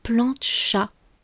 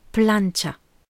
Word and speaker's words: plancha: Elle avait dit plancha, alors qu'on prononce plancha. Alors, si vous connaissez pas bien l'espagnol, vous entendez peut-être pas la différence, mais je vous assure qu'il y en a plus d'une, et je vais vous les donner. Déjà, l'accent tonique en espagnol, il tombe sur le premier A, celui de plat, alors qu'en français, il tombe sur le deuxième A, celui de chat.